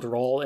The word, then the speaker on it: Raul: And basically the protagonist is a guy called Raul Endymion, spelt Raul, but the novel goes out and say it rhymes with Paul, which is funny.